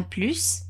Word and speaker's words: plus: Pronuncie esse S do plus, hein? En plus, vous voyagerez en plein hiver.